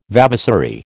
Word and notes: vavasory: American English

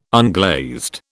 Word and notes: unglazed: British English

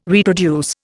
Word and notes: reproduce: Indian

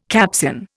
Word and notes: Capsian: Indian

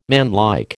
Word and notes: manlike: American English

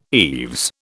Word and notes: eaves: British English

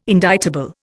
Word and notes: indictable: Indian

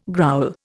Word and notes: growl: Indian

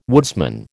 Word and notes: woodsman: American English

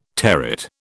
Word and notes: territ: British English

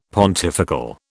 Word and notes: pontifical: British English

